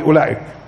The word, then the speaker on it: أولئك: زي مثلا الواو في اولئك مش هيك؟ والالف في اولئك مش مكتوبه وتلفظ ولا مش هيك؟ اولئك مش هيك؟ هي الالف ملفوظه ومش مكتوبه والواو مكتوبه ومش ملفوظه تمام؟